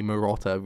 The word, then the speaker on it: Marotta: Like, clearly that's like, it rhymes with his name, Jay Marotta, Ray Liotta.